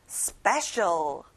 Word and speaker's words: special: 특별한 special. 스페에 강세를 넣어보는 거예요. Special.